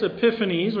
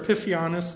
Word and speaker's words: Epiphanes: So part of the preterist, or those who view this as fulfilled near to the time it was given, will say Antiochus Epiphanes, or Epiphanes, depending on which syllable gets the emphasis, developed, he basically ruled the territory that Jerusalem was in.